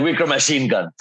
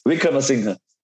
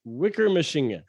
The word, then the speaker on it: Wickramasinghe: The way you said it is like Wicker machine gun. Like machine gun. How do you say it? – Wickramasinghe. – Wickramasinghe.